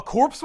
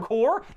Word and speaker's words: Corps: Did you mean core or war? – Or both. – No, Corps War. – What's a Corps War? C-O-R-E? – No. C-O-R-P-S-W-A-R. – A corpse war? – No. – Pronounced core.